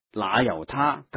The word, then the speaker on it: 那由他: 那由他，传统读作罗由他，但是梵文是 Nayutta，所以应该读成那由他更为贴切的。